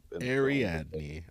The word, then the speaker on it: Ariadne: Ariadne. I can't spell it, but I can Ariadne. Okay. When he explains to Ariadne,